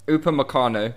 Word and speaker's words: Upamecano: Is it Upamecano? – Upamecano.